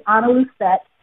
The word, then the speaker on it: Analusette: So like Yolanda mentioned, my name is, it's actually Analusette.